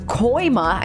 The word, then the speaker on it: Kooima: I am joined in the studio from Hope Haven Marketing Manager Brooke Koima.